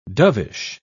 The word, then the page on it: dovish: British English